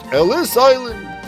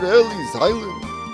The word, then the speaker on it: Ellis Island: But I'm afraid it's pronounced Ellis Island, not Ellie's Island.